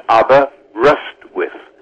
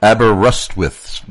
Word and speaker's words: Aberystwyth: How did I do pronouncing Aberystwyth? – Well, not bad. It's Rust. I think it's built around Aberystwyth. – Okay. – that's the easiest way. Hit the rust. – Aberystwyth, Wales.